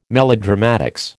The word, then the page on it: melodramatics: American English